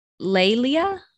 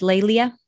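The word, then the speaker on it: Leilia: So there's one called the Empress Lelia, I think is how oh. it is pronounced. It's L E I L I A. So wouldn't you think that's Lelia? – Sure.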